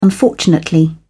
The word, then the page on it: unfortunately: British English